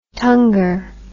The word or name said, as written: tungar